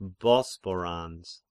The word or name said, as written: Bosporans